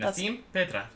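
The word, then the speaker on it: Nasim Pedrad: It stars Nassim Pedrad. – Mm-hmm. Nassim Pedrad.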